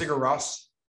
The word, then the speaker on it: Sigur Rós: Shigeru, I never even know how to pronounce that. Shigeru's at 108. – That's Sigur Rós.